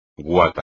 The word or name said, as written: guata